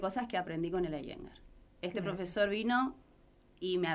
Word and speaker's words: Iyengar: Y bueno, y gracias a unos chicos que trajeron a la Iyengar a Posadas. – ¿Cómo es que se pronuncia? – Iyengar.